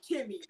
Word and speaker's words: Kemi: And I spell it K-E-M-I. Kimmy. – K-E-M-I.